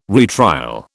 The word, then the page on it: retrial: British English